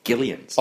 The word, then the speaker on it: Gillian: I believe she's one in a fast-growing group of hard G Gillians.